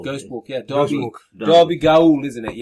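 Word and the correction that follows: Gaol: It's pronounced jail. – But it's not spelled like that, Gaul.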